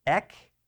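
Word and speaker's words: ek (ἐκ): It is pronounced exactly how it looks, ek, right, ek.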